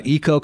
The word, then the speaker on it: EKO: Eco Compost, EKO is how it's spelled.